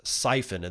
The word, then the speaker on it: Psiphon: and Canadian proxy service Siphon, and that's spelled P-S-I-P-H-O-N.